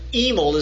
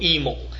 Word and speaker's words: Hymel: But Daryl Emel is spelled H-Y-M-E-L, Daryl Emel.